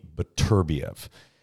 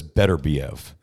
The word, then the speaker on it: Beterbiev: He told Arthur, when I announced him, he told me, because I'm very specific about asking about names and pronunciations, and he told me Beterbiev. So that's what I say. – Yeah, but most everyone says Beterbiev.